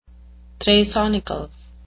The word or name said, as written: thrasonical